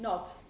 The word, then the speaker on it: knobs: That's different. Knobs. It's knobs. A silent K. – How I thought he was saying knobs. – Yes, it's a bit difficult that one. It's a silent K. You don't like knees.